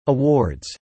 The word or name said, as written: awards